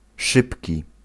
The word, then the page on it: szybki: [ˈʂɨpʲkʲi]